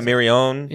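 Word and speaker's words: Mirrione: So, despite the editor, Stephen Mirioni? How do you pronounce it? Yeah, Mirione. Yeah.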